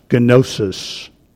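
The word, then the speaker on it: gnosis (γνῶσις): Our word science is a translation of the Greek word, which would be pronounced gnosis.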